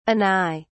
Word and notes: anigh: British English

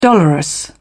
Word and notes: dolorous: British English

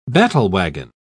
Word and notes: battlewagon: British English

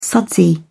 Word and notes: sudsy: British English

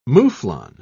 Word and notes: mouflon: British English

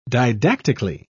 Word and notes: didactically: British English

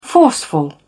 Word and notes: forceful: British English

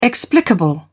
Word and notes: explicable: British English

